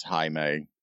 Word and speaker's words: Jamie: It's Jaime. – Okay. Yeah. – That's how they pronounce the J sound in Britain.